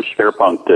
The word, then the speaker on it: Schwerpunkt: And he calls it, I'm going to try to pronounce it, the Schwerpunkt. Schwerpunkt. Is that right? – Yes, it's a German term, Schwerpunkt.